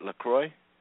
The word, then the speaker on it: LaCroix: And am I pronouncing your last name right, LaCroix? – Yes, correct. If we were in France or Canada, they would say LaCroix, but here it's LaCroix, like the island of Saint Croix.